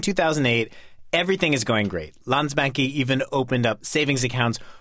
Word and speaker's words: Landsbanki: Landsbanki.